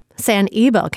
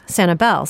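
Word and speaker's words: Sanibel: Sani-belle can be traced back to the 1500s. Back then, the island was noted in charts as S-Nivelle. But these days, Sani-belle seems to be the preferred pronunciation.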